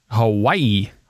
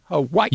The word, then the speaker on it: Hawaii: How do you say that state he's from? – Hawaii. – Hawaii. – You say it very interestingly. I've never heard it pronounced the way you pronounce it, Clark. Hawaii. – Well, you'd have to be a Hawaiian.